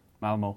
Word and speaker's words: Malmö: You said Malmö. I thought it was just a posh pronunciation of no. – Malmö. – I think you find it's the correct pronunciation.